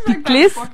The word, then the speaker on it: piclis: I like that pork, the griot, yeah, griot with the sauce piclis. – Yeah, am I saying it right? Woo! Yes.